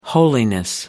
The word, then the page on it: holiness: American English